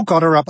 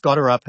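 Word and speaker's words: Gotterup: Ja, es ist übrigens gut, dass du Goderup ausgesprochen hast. Ich habe es natürlich ein kleines bisschen versaubeutelt gleich zu Beginn. – Ich habe das von den Amis übernommen. Okay, gut, die haben irgendwie Chris Goderup gesagt.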